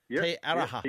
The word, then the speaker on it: Te Aroha: Then you come back down and down into Te Aroha. – Yep. – Is that how I pronounce it? – Te Aroha.